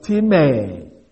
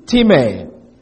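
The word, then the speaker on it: Timē (τιμή): I write it T-I-M-E-E to show it is not actually sub transliteration. We just write it as T-I-M-E. But it's a long E in the Greek, and they should sometimes to avoid any kind of confusion. It's T-I-M-E-E. Timē.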